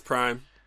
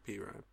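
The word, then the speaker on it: PRhyme: It's Prime. They want it pronounced Prime. – Okay. DJ Premier. – But it's capital PR. Like, yeah. So it looks like P-Rhyme.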